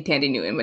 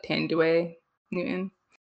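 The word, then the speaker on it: Thandiwe Newton: Nico Parker, who is the daughter of, I know she pronounced her name differently now. It used to be Tandy Newton, but now it's like Tanduay Newton.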